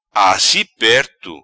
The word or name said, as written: Assi Perto